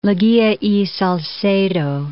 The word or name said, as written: Leguia y Salcedo